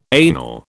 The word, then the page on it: anal: British English